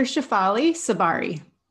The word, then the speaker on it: Shefali Tsabary: Dr. Shafali? – Yes, it's Dr. Shafali Sabari.